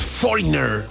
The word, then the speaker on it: Foreigner: O Foreigner, como le decía a mucha gente. Foreigner. No, es Foreigner. Esa es la pronunciación correcta. No soy el más experto yo en inglés, pero sí sé que es Foreigner.